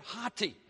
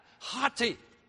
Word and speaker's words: hoti: In the original language, it's the word hati, okay? Phonetically spelled H-O-T-I, hati.